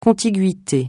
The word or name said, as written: contiguïté